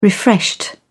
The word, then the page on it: refreshed: British English